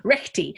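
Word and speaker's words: rekhti: This I was going to ask you, what is recti? Is it recti? Okay. Is that how you say it? – Recti. Okay. Yeah.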